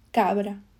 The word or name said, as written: cabra